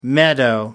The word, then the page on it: meadow: American English